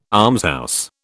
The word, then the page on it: almshouse: British English